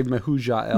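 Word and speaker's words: Mehujael: To Enoch was born Irad, and Irad fathered Mahujilla. – Nice. – How do you say that? – I would say Mahujael.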